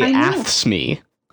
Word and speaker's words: AFSCME: I say AFSCME. – Yeah, I'd say AFSCME. – Kind of like acne. – Yeah. So I do pronounce the C as a hard C. So AFSCME.